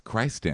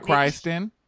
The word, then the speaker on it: Christin: And her name is spelled like Christ. – Yes. Christin. So, like a verb. Hey, where have you been? Oh, I've been out Christin.